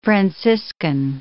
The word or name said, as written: franciscan